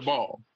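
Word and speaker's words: Daboll: Brian DeBall. – DeBall? – Yeah. Just look at me and say, Rodney, you're DeBall. – Look, DeBall.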